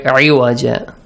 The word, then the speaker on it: عوجا: Pronounce the عين correctly. عوجا.